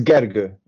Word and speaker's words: Gergo: So, Giorgio, that's how to say it? – It's Gergo, actually. Gergo.